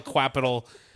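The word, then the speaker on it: Qapital: It's Q A P I T A L, but I call it Quapital.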